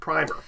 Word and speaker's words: primer: Then people said, there's a consonant in the middle and we make it primer. – And now, interestingly enough, apparently, because the British are the ones that started with the primer, pronouncing it with the long I. Americans stuck with the primer.